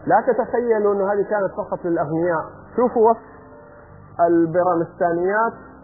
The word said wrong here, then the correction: بيمارستان: أنا قاعد أنطقها غلط ترى. بيمارستان هي مو بيرامستان. بيمارستان.